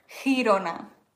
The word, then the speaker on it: Girona: Also G vor I wird wie CH gesprochen. Girona vor A, O oder U wird wie G ausgesprochen, aber vor I, Chirona.